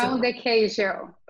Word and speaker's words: pão de queijo: Pão de queijo.